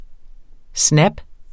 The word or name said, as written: snap